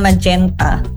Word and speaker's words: magenta: Tulisannya magenta ya, tapi bacanya magenta.